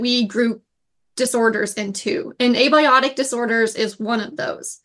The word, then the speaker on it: abiotic: And how do you pronounce this? Abiotic issues, is that right? – Yeah, abiotic, yep.